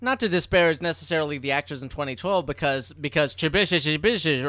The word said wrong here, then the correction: Chiwetel Ejiofor: or whatever his name, however you pronounce it, Chiwetel Ejiofor.